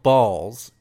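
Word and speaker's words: Bawls: I don't know, have you ever had the beverage, and I'm going to pronounce it correctly, it's called Balls, it's B-A-W-L-S,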